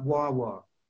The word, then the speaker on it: guagua: They have buses in Tenerife near the Canary Islands that they call Wawa, which is G-U-A-G-U-A, and you pronounce that Wawa.